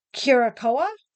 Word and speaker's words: Curacoa: During the war, the Queen Mary was involved in a tragic accident, colliding with the escort ship, the HMS Curacoa. Do you think I said that right? – Yes. – C-U-R-A-C-O-A.